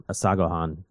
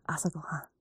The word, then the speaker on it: asagohan: Asagohan. – Asagohan.